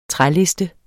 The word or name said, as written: træliste